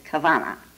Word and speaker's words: Kavana: It was a way that combined intent, and their word for it is Kavana, and it's spelled several ways. It's K-A-V-A-N-A, K-A-V-V-A-N-A, K-A-W-W-A-N-A, K-A-W-A-N-A.